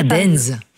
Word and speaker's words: Deinze: Et il y en a un, je m'excuse pour la prononciation, c'est Dinze. Ça s'écrit D-E-I-N-Z-E. – Ah, Dinze. – Dinze, pardon.